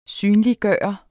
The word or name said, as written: synliggøre